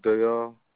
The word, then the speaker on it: Dayal: This is James Dayall. – J-A-M-E-S. Could you spell that last name? – D-A-Y-A-L. – D-A-Y-A-L.